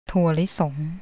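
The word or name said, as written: ถั่วลิสง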